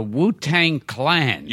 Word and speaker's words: Wu-Tang Clan: And I think you're mentioned, if I'm pronouncing it correctly, that you're mentioned in the Wu-Tang Clan. – You got it.